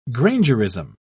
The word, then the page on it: grangerism: British English